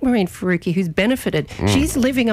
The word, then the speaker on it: Faruqi: The name rhymes with kooky? – Yes, huh? I think so. Yes, Senator Maureen Faruqi is what we're talking about.